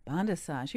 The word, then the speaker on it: Bondassage: Well, and it's spelled like bondassage, just so you can Google it. Bondassage. – B-O-N. – But it's bondissage, like a massage, right?